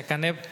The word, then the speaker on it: Canep: Al leerlo juntos se pronuncia canep Y para traducirlo un poquito, se, podemos decir que Canep es colmillo de, serpiente en lo, de origen maya.